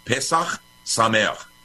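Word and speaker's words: Pesach Sameach (פסח שמח): Okay, how do you say happy Passover in Hebrew? Pesach Sameach.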